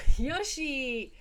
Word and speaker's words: Yossi: Hey, Yoshi.